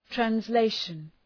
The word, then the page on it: translation: trænz’leıʃən; træns’leıʃən